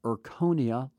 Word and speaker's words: Erchonia: Now, or you can find a doctor that has this Erconia laser. It's spelled E-R-C-H-O-N-I-A, cold laser.